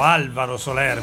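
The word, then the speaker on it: Álvaro Soler: Abbiamo ballato con un altro idolo delle teenager, questa volta del secondo decennio degli anni 2000, l'Alvaro Solero, Alvaro Soler, perché in spagnolo si pronuncia Alvaro.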